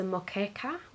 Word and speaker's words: moqueca: I've had them at the they do something, I think it's pronounced the moqueca, which is a Brazilian seafood stew which has scallops, shrimp and whitefish, with a coconut lime sauce and steamed rice.